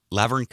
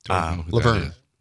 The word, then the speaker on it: Laverne: 1972, Laverne Cox, don't know if I'm saying that right. – Laverne. – Laverne, that's what it is.